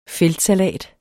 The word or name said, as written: feldsalat